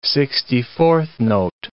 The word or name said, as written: sixty-fourth note